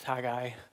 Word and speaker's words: Haggai: So Haggai. And if I say Haggai, I don't mean to. It's Haggai.